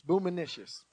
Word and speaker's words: Boomalicious: And her name, Boomalicious, not Boomalicious, Boomalicious, all right? Rhymes with Mamalicious, but Boomalicious, all right?